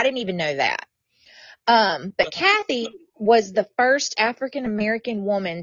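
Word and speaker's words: Cathay: It's spelled Cafe, but I believe it's pronounced Kathy.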